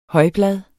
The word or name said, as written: højblad